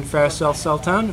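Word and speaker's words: Faris Al-Sultan: Today, day 2 for us, we have 2005 Hawaii champion Ferris Al-Sultan.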